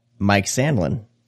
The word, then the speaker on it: Mike Sandlin: BJ has stepped out, and along with us, Mike Sandlin.